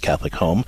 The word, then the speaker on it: Catholic HOM: It's pronounced Catholic Home, but it's spelled H-O-M.